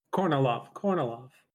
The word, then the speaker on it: Kornilov: In July, we get Kamilov? – Yeah. – Did I say that right? – Is it Kornilov? – Kornilov. That's right.